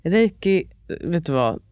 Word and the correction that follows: Reiki: Din version av ja, Reiki.